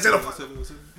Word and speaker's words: xenophobia: I'm gonna lie to you, you're not even to make a joke out of it, yeah, but like, I've actually only just learned how to pronounce it this week. – Mm. – I've been seeing the word... – I'm dead. – I'll be like, exoniophobia? What? Niggas will be timing it bad like xenophobia.